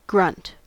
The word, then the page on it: grunt: American English